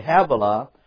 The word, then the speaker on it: Havilah: that's pronounced H-A-V/A/L-A, Havilah, unto Sir, S-I-R, Sir, that is before Egypt, as thou goest towards Assyria, and he died in the presence of all his brethren.